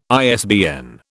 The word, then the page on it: ISBN: British English